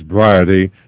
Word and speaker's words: Briety: Well, Dr. Stan back here, and our guest is Dan, and I want to, it rhymes with sobriety, so it's Briety,